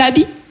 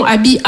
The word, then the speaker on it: Aby: Non, comme je vous ai dit, Abby, c'est mon prénom. Abby, A-B-Y, trois lettres, deux syllabes.